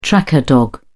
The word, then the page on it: tracker dog: British English